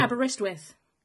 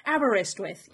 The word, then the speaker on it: Aberystwyth: Aberystwyth. – Oh, you got it. Thank you. – Aberystwyth, yes.